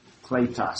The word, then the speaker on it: kletos: How do you say that? – Yeah. Oh, kletos. – Kletos? – Kletos. Kletos. Sorry about that. Kletos. K-L-E-T-O-S. Transliterated.